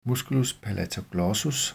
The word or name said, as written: m. palatoglossus